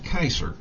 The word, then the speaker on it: Caesar: It means Caesar, which isn't pronounced Caesar, it's pronounced Kaiser.